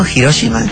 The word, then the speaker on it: Hiroshima: Perdón, locutor, ¿cómo, por qué dijo Hiroshima? – Porque se dice así.